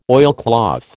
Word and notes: oilcloth: American English

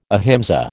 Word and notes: ahimsa: American English

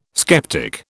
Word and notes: skeptic: British English

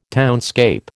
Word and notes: townscape: American English

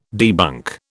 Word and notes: debunk: British English

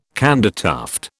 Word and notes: candytuft: British English

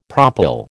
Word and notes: propyl: American English

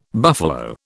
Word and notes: buffalo: British English

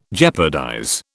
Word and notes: jeopardize: British English

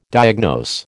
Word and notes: diagnose: American English

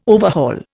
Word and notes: overhaul: Indian

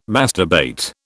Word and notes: masturbate: British English

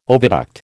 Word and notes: oviduct: American English

